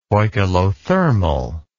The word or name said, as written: poikilothermal